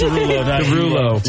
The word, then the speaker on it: Derulo: Right in solo, Jason Derulo. Did I say his name? Yes. Derulo.